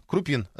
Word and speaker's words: Крупин: Ну да, так вот, Крупин, Крупин, 002 утверждает, что это правильное ударение.